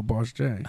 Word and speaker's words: Boss Jay: Oh, Boss J. – How you spell that? – B-O-S-S-J-A-Y.